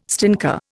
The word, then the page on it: stinker: Indian